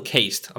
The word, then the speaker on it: caste: They have a ruling council known as, and I'm sorry, I'm probably going to butcher this name, Ethereal Castle, or is Ethereal Caste, I'm sorry.